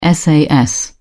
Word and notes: sas: British English

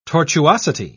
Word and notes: tortuosity: British English

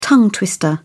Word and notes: tongue twister: British English

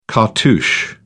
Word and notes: cartouche: British English

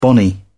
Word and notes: bonny: British English

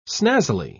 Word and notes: snazzily: British English

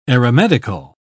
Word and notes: aeromedical: British English

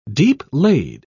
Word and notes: deep-laid: British English

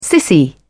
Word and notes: sissy: British English

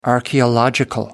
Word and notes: archaeological: American English

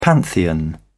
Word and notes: pantheon: British English

